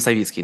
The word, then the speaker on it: Савицкий: Только я Вадим Савицкий, но это не страшно. – Простите, пожалуйста, просто я привыкла эту фамилию слышать именно с ударением «Савицкий». – Все прекрасно, я привык к тому, что ее не всегда произносят правильно.